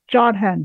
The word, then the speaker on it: Jodhan: Jodhan, J-O-D-H-A-N.